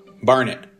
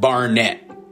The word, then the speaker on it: Barnet: Like it rhymes with darn it. Barnet, not Barnett.